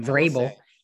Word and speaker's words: Vrabel: Mike Vrabel. I cannot pronounce his Vrabel. – Name. – That is a hard name. – Vrabel.